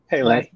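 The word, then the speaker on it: Pelé: Soccer player, Pele. Did I say it right? Pele. – Pele.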